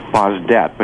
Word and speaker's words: Bazet: Charles, the grandson, pronounces his last name Bazdet, but you are more correct if you looked at the French term.